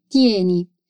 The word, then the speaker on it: tieni: Di nuovo, attenzione à pronuncia da lettera T in tieni.